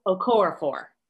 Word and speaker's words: Okorafor: The title is Remote Control, and it's Nnedi Okorafor. Have I said that right? – Okorafor.